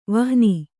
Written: ವಿಹೀನೆ

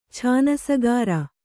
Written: ಛಾನಸಗಾರ

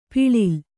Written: ಪುಳಿಲ್

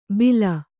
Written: ಬಿಲ

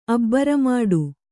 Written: ಅಬ್ಬರಮಾಡು

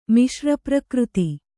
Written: ಮಿಶ್ರಪ್ರಕೃತಿ

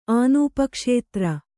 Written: ಆನೂಪಕ್ಷೇತ್ರ

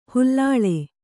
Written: ಹುಲ್ಲಾಳೆ

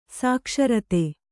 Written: ಸಾಕ್ಷರತೆ

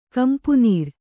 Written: ಕಂಪುನೀರ್

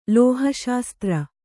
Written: ಲೋಹಶಾಸ್ತ್ರ

